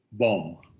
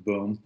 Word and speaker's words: Boom: Well, why don't we read a quote from Elder Hans Boom. He doesn't pronounce it boom, but it's Boom. He's Dutch, so.